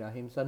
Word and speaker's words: Ahimsa: So, Bill is asking, is the A in Ananda a negation like the A in Ahimsa? No. Ananda, this is A means A Samanta, complete joy. This is not a negation of joy. Ahimsa is a short A, Ahimsa.